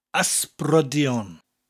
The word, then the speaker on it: ysbrydion: Today's word is ghosts, and the Welsh word for ghosts is ysbrydion.